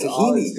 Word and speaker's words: tahini: Tchina, but in the Arab-speaking world, it's not pronounced tchina. It's tahini.